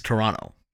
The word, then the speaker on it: Toronto: For everyone outside of Toronto, it is Toronto to them. For us, you pronounce the second T. – Yeah, for us, it's Toronto.